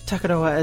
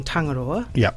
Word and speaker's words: Tangaroa: So this morning, with the moon in Takaroa phase, Takaroa is our way of pronouncing Tangaroa, the ocean entity. I say that down here in the south.